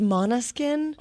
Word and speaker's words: Måneskin: I think it's pronounced Måneskin. – Okay. – Because it's got the weird little thing above the E.